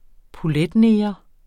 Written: poletneger

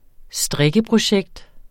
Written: strikkeprojekt

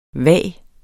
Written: vage

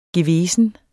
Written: gewesen

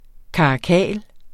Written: karakal

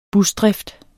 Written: busdrift

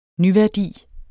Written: nyværdi

